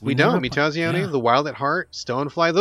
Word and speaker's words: Mutazione: Interesting that Mutazione, am I saying that right? – Mutazione.